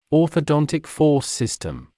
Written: orthodontic force system

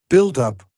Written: build-up

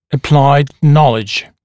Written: applied knowledge

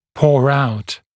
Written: pour out

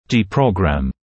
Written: deprogramme

